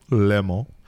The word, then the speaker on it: Le Mans: He won this little race in a French village called Le Mans uh, in 1990. – You said it right.